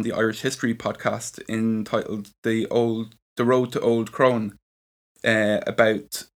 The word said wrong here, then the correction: Old Croghan: Old Croghan. – Old Croghan.